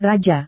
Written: raja

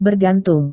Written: bergantung

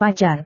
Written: pacar

